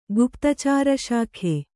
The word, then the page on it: ಗುಪ್ತಚಾರಶಾಖೆ: guptacāra śakhe